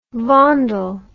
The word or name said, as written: Vondel